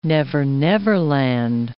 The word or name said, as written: never-never land